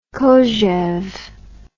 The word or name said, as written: kojeve